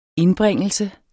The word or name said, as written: indbringelse